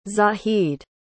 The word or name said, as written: zahid